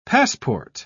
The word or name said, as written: passport